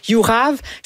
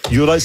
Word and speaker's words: Juraj: Personnellement, moi, j'ai de la difficulté avec Jurav Slavkovski. – Juraj.